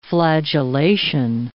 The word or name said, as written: flagellation